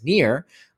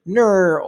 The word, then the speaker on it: Nir: But when I had to, you know, when people heard my name and I said my name is Near, then that sounds like, you know, my name is spelled N-I-R, but people thought I was saying N-E-A-R.